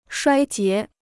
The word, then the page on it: 衰竭: shuāi jié